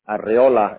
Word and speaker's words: Arreola: So, Leonardo, I spell that L-E-O-A-N, How do I spell Leonardo? – It's L-E-O-N-A-R-D-O. – Last name? – It's Leon. Arreola. It's A-R-R-E-O-L-A.